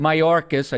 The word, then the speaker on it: Mayorkas: But I got to tell you something you can relax because Homeland Security, Handioras uh, Mayorkas, I just butchered his name.